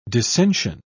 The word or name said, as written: descension